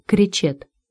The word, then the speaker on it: Кречет: Вот тут-то Светлана и придумала мужу псевдоним — Леон Кречет, с ударением на последний слог.